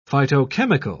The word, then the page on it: phytochemical: British English